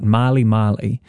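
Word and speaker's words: Mali Mali: So the artist that we're talking about, Marley Marley, that is spelt like the country repeated twice. M-A-L-I space M-A-L-I.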